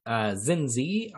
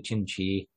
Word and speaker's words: Chinchi: So I pronounced it Zinzi on the first iteration of this episode many moons back last year. Zoithrus promptly corrected me and let me know it's actually Chinchi with like a CH sound.